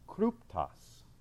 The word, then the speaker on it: kryptos: the Greek word for secret in verse 10 is pronounced kryptos. But you don't have to use your imagination. What does that sound like? Where the Lakers play their games now? Crypto.